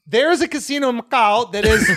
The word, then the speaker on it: Macau: Macau.